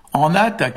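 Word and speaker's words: anat: Et donc en breton d'aujourd'hui, enat, qui s'écrit A-N-A-D ou A-N-A-T, D c'est plus proche de la prononciation, hein.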